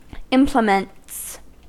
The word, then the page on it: implements: American English